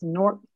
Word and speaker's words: Newark: It's Newark, if you live here, N-O-R-K. But okay. People know I'm not from here because I say Newark.